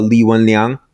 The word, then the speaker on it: Li Wenliang: let's talk a little bit about you know, like Dr. Li Wenliang. Is that how I pronounce his name? – Yeah.